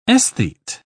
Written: aesthete

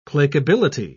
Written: placability

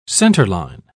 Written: centerline

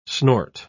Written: snort

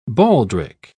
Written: baldric

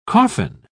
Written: coffin

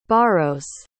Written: borrows

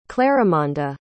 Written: clarimonda

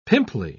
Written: pimply

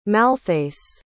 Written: malthace

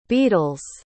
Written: beadles

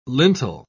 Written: lintel

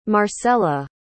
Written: marcella

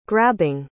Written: grabbing

